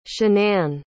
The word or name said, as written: shannan